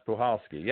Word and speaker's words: Puchalski: It's not, because I was pronouncing it Pukalski to Pulhowski. I wasn't sure, but the C is silent, and the correct pronunciation is Pulhowski.